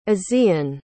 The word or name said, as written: asean